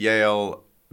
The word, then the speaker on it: Yale: Yale. It's one syllable.